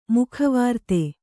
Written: ಮುಖವಾರ್ತೆ

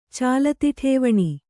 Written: ಚಾಲತಿಠೇವಣಿ

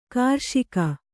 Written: ಕೃಷಿಗೈ

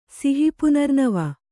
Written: ಸಿಹಿಪುನರ್ನವ

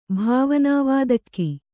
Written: ಭಾವನಾವಾದಕ್ಕಿ

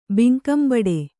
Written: ಭಂಗಂಬಡು

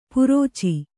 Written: ಪರಚು

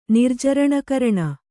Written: ನಿರ್ಜರಣಕರಣ

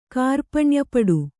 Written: ಕಾರ್ಪಣ್ಯಪಡು

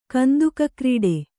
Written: ಕಂತುಕಕ್ರೀಡೆ

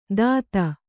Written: ದಾತ

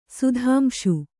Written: ಸುಧಾಂಶು

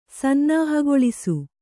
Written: ಸನ್ನಾಹಗೊಳಿಸು

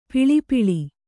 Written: ಪೆಳ್ಪಳ

